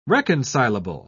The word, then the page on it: reconcilable: British English